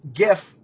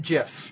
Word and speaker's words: GIF: This isn't a question, but I'd like to say that it is 100% pronounced GIF, not JIF.